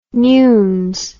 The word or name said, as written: newnes